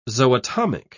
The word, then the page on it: zootomic: British English